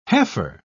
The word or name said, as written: heifer